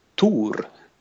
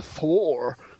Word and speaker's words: Thor: No, ehm, che fra l'altro si pronuncia Tour. Tour. Eh, invece nel film si pronuncia Thor. – Nah, perché quelli sono gli americani de merda, no? no eh, sì, eh, tour.